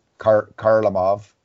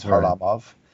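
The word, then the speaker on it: Kharlamov: So, you know, it's not Valerie Karlamov, it's Harlamov.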